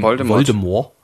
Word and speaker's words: Voldemort: Rowling, Voldemort. – Das T ist nämlich angeblich stumm.